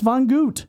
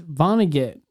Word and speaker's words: Vonnegut: How do we pronounce Von Gut? – It's Vonnegut. – Oh. – Kurt Vonnegut, the author.